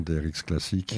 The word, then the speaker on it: DRX Classique: Bon, pour ceux qui ont besoin d'avoir les trucs sous les yeux, on rappelle les réseaux sociaux, Instagram, DRX Classique. – Exact, comme ça se prononce, mais sauf que c'est pas Classique QE, c'est CLA2S6IC.